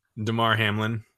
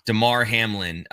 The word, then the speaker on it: Damar Hamlin: Damar Hamlin. – Thank you. Damar Hamlin.